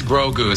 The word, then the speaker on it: Grogu: Yeah, that's Grogu.